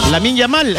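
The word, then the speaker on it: Lamine Yamal: Lamin Yamal, ese jugador de España, hombre.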